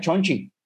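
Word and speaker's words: Shang-Chi: este, yo sé, que por lo menos cuatro de cinco aquí estamos aparentemente pompeados para ver a Chang-Chi o Chang-Chi, como dice Picón. Escuché que supuestamente no se dice así. Si lo oh. pronuncias como lo dicen los chinos, es Chonchi.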